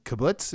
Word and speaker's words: kibbutz: living on a kibbutz.